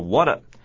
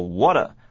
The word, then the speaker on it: What: 第二点呢，就是阿丽说的第二句话里面，刚开头的两个字啊，What a 要念作 What。 What 是怎么变成的呢？首先把 H 拿掉不念，WH 的字呢，H 不要念就对了。再来是 T 要连在一起念，并且软一点，念作 What。